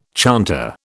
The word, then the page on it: chanter: British English